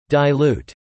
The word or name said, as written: dilute